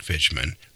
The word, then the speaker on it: Fichman: which is Frederick Fishman. Last name is spelled F-I-C-H-M-A-N.